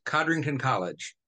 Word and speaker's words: Codrington College: He has taught courses on the New Testament at several institutions, including, is it Codrington College? How do you pronounce that name? – Yes, Codrington College.